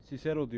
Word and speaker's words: Cicero: Şimdi bunun okunuşunu ben Cicero diye okuyorum. Sen Kikero diye okuyordun.